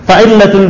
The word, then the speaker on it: fa'illatul: Fa'illatul bina ya, masyaallah. Barakallahu fikum. Ya, jadi bukan fi'latul ya. Saya bacanya kok fi'latul bina. Iya, benar. Fa'illatul bina.